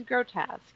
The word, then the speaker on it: grotesque: And she was like, it's grotesque. It's French. So you don't pronounce the U-E on the end. It's just grotesque.